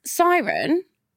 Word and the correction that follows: Sarnia: It's much worse than Siren. It's Sarnia, which sounds like Narnia with more sandwiches.